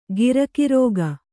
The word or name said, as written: ಗುರಿಗಾರಿಕೆ